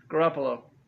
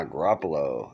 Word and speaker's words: Garoppolo: Garoppolo. – Ah, Garoppolo.